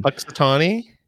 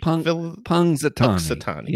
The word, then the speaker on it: Punxsutawney: Punxsutawney? – Thank you for saying it for me. Yes. I've been trying to pronounce it. Now tell us where it's at. – Punxsutawney's and Punxsutawney. Yeah. – P-U-N. – Punxsutawney. – P-U-N-X.